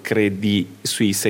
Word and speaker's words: Credit Suisse: Stessa cosa per Credit Suisse, dove in Ticino si pronuncia la T finale, quando Credit Suisse è chiaramente un nome francese.